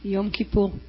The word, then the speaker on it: Yom Kippur: Yom Kippur. – Yom Kippur. Yom Kippur. Yom Kippur. Parece japonés, ¿no? Yom Kippur, ¿no? Gracias a Dios que tenemos nuestro representante de la Tierra Santa aquí. Yom Kippur, y yo he escuchado toda mi vida de Yom Kippur.